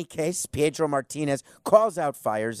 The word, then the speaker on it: Fiers: He said that Mike Fiers, I think it's E-I-R-S or I-E-R-S, and I think it's pronounced Fiers, not Fears.